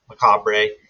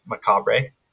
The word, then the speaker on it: macabre: It's a French origin word. – I told you. – In French, it is pronounced macabre. And in British English, it is also pronounced macabre. But the American English version is macabre. And, well, I'm not about to change up what I say now, so it's macabre.